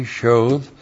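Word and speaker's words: shoth (שֹׁד): S-H long O-D, shoth.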